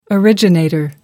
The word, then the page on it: originator: American English